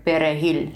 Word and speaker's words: perejil: To determine who was Haitian, soldiers with machetes asked dark-skinned people to say the word perejil, which is Spanish for parsley. For Creole-speaking Haitians, the R sound was difficult to pronounce, and a slip of the tongue became a death sentence.